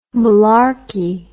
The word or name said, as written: mullarkey